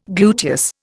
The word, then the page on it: gluteus: Indian